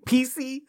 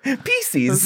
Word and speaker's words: Pieces: You are so attached to that man's name being Reese that you're going to start pronouncing Piecey. – Pieces.